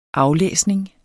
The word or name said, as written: aflæsning